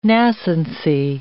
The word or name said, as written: nascency